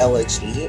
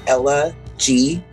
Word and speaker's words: Elegy: I am Elegy, and it's actually pronounced like Ella G.